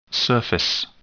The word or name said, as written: surface